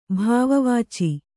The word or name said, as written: ಭಾವವಾಚಿ